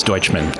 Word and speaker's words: Dutchman: And did I pronounce that correctly? – Not really. – I'm bad with names. – Okay. – I really am. – I don't get offended, but it's Dutchman.